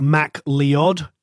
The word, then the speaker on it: MacLeod: So if the Americans are listening, it's like Mac Leod. – Oh, is MacLeod. – That how, yeah, is that how, you know, they say it? – Yeah, MacLeod. They say Mr. MacLeod. Yeah, he always jokes about it. He is American, but he says he always has to explain to him how to pronounce Scottish names.